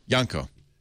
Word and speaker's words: Yanko: Yanko.